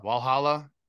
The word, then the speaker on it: Valhalla: Valhalla.